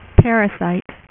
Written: parasite

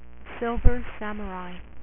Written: Silver Samurai

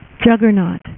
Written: juggernaut

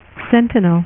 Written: Sentinel

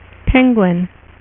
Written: Penguin

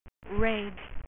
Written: Rage

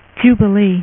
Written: jubilee

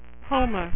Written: Homer Simpson

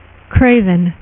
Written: Kraven

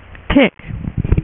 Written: Tick

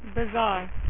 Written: bizarro

